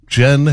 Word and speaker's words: Jyn: J-Y-N, pronounced Jen as in the drink.